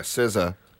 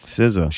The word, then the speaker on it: SZA: Next up, how do you is this? Do you SZA. – Say that's how you say it? – Yeah, SZA. – All right, SZA.